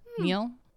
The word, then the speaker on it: Nils: N-I-L-S. How do you pronounce that? Neil? – Yeah, like Nils.